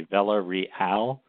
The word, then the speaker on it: Villareal: Uh, people ask my name. I say Vela Real. Uh, that, that's how you say it in English, and so that's good right there.